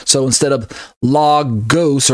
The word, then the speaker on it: Logos: Logos, L-O-G-O-S, pronounced Logos or Logos by other Christians, but I choose to pronounce the Greek word Logos. Because the two letters are omicrons, there's no omega in there. So there's two letter O's in the Greek. There's an A, like the O in doctor, and it's the one that looks like the letter O. That's pronounced A.